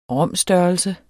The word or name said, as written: rom-størrelse